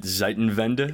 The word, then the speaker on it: Zeitenwende: Is it Zeitenwende? – Yeah, which no one can pronounce in the outside world, but it was actually very good how you pronounced it, but it's so that's how it's called, a changing of times.